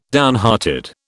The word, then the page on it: downhearted: British English